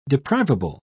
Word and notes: deprivable: British English

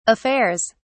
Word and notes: affairs: British English

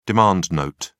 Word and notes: demand note: British English

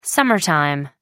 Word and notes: summer time: American English

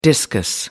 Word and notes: discus: American English

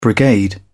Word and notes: brigade: British English

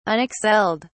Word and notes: unexcelled: British English

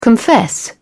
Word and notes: confess: British English

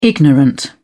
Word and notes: ignorant: British English

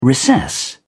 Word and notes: recess: British English

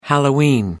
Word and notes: halloween: American English

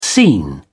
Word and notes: seen: British English

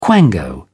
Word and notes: quango: British English

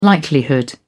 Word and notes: likelihood: British English

